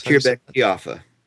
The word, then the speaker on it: Khirbet Qeiyafa: Well, let's shift from that to something much more specific, and the particular dig site that you've worked with, Khirbet Qeiyafa, I believe that's... – Yes, Khirbet Qeiyafa, yep.